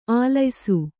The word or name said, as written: ಆಲಯ್ಸು